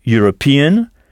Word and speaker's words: European: Si un Anglais se dit an Englishman et le mot pour européen est European avec le son U au début, ça s'écrit E majuscule European, European.